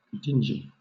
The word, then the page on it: dingy: British English